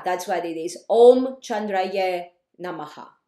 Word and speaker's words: Chandraye: The YA or YE, it's pronounced ye, is more formal. Chandraye.